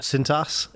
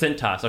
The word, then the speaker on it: Cintas: How do you say that? – Cintas. – Is that what it says? Cintas.